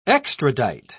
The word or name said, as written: extradite